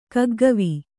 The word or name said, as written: ಕಗ್ಗವಿ